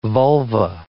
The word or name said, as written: vulva